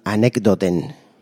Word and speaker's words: Anekdoten: Ellos son Anekdoten, así como suena.